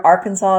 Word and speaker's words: Arkansas: The word Arkansas is pronounced with a silent S at the end, and it's against the law to mispronounce the word Arkansas while in the state.